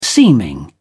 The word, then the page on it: seeming: British English